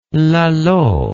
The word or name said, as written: lalo